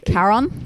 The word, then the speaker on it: Charon: I think it's pronounced like a K sound at the start, right? Charon.